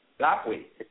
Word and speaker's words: Lapwai: First of all, from, is it, let me make sure I'm saying this right, is it Lapwai? – Lapwai.